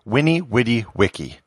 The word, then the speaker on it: Veni Vidi Vici: Caesar's famous quote, Veni Vidi Vici, was originally pronounced Winnie Witty Wiki.